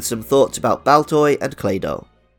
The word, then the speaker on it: Claydol: The name, Claydol, quite literally comes from Claydol, but it also rhymes with dreidel, a type of spinning top used during Hanukkah celebrations in Judaism.